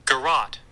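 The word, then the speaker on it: garrote: Garrot.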